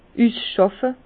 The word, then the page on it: üsschàfe: Bas-Rhin; Herrlisheim